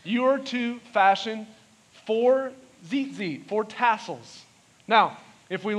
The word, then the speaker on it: tzitzit: In the Hebrew, they're spelled T-Z-I-T-Z-I-T. Now, I read that as T-Z-Z-I-T, but that's not how you say it. That's not it. It's called Zitzi. Can you say that?